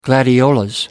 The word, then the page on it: gladiolus: American English